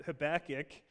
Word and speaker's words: Habakkuk: Habakkuk, of course, is a book that many people aren't familiar with, and maybe up until today you didn't actually know how to pronounce the name of this book. It's not Habakkuk or anything like that. It's Habakkuk. Habakkuk chapter 2.